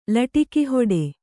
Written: ಲಟಿಕಿಹೊಡೆ

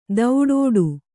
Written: ದೌಡೋಡು

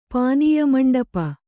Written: ಪಾನೀಯಮಂಡಪ

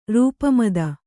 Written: ರೂಪಮದ

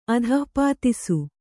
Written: ಅಧಃಪಾತಿಸು

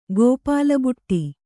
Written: ಗೋಪಾಲಬುಟ್ಟಿ

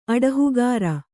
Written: ಅಡಹುಗಾರ

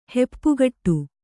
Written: ಹೆಪ್ಪುಗೊಡು

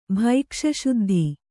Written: ಭೈಕ್ಷಶುದ್ಧಿ

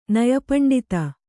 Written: ನಯಪಂಡಿತೆ

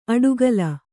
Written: ಅಡುಗಲ

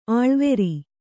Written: ಅಳವರಿ

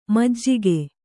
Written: ಮಜ್ಜಿಗೆ

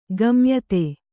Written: ಗಮ್ಯತೆ

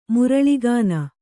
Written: ಮುರಳಿಗಾನ

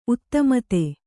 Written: ಉದುಮದ

